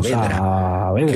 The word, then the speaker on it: Saavedra: Oscar Saavedra. Digo Saá porque se escribe así, ¿no? Con doble A. – Claro, Saavedra.